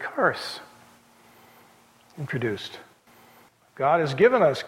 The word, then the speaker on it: charis: Yeah, well, it's an X. It's K. Yeah, K in the Greek. Charis. We would put a CH with a hard C.